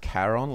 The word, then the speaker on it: Charon: Yeah, it's I think it's pronounced Charon, like, but it's C-H-A-R-O-N.